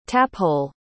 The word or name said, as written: taphole